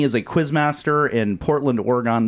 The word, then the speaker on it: Oregon: Oregon. I was corrected on the pronunciation there. It's not Oregon. – No. – That's how some dumbass Floridian kid would say it. It's Oregon.